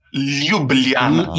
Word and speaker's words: Ljubljana: No, it starts with L. Ljubljana.